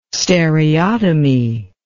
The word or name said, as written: stereotomy